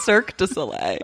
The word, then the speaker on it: Cirque du Soleil: I think it's pronounced Cirque du Soleil. – Oh. – It's French. Okay.